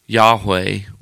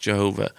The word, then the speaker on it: Yahweh: The Jews did not write the name of God, Yahweh, or sometimes pronounced Jehovah.